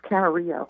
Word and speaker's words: Camarillo: And we'll take now calling in from, is it Camarillo, California? Is that how you pronounce it? – It's Camarillo.